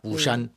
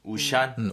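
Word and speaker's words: 우샨 (巫山): 사실 그것도 우리말 발음이 무산이고 네네. – 실제로 중국 발음은 우샨입니다. 우샨. – 네. 우샨.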